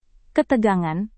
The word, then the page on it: ketegangan: indonesian